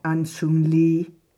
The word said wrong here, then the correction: Aung San Suu Kyi: oh, Aung San Suu Kyi.